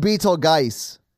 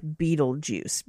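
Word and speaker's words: Betelgeuse: It's pronounced Beetlegeist. – I can barely pronounce English words. – That is true. We've learned that. – There's a number of pronunciations listed for it. The most common pronunciation is popularized for sounding like Beetlejuice because it's something that people can kind of wrap their minds around saying because it sounds like other things.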